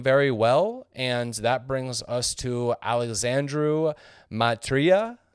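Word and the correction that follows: Mitriță: Matrita. – Probably butchered that, and you nailed that.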